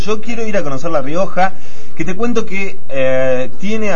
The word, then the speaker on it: La Rioja: Además está decir que la capital de la provincia lleva su mismo nombre, la ciudad de La Rioja.